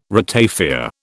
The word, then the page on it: ratafia: British English